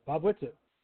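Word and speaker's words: Bob Whitsitt: I'm probably butchering his name, and I apologize for that. – No, you got him all right. – Clyde then Bob Wizzit? – Okay. Yeah, Bob Wizzit.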